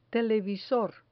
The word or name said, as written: televisor